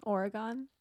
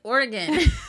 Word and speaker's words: Oregon: Oregon. – It's Oregon. – It's Oregon. – I mean, it's literally spelled like Oregon, but like it's Oregon.